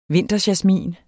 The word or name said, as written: vinterjasmin